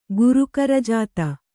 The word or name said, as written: ಗುರುಕರಜಾತ